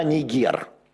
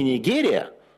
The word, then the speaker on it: Нигер: Есть такая страна Нигер. Не Нигерия, Нигер только. – Или Нигер. Нигер. Но слово «нигер» вызывает у моих американских собеседников... – Да, но ударение правильно на «нигер».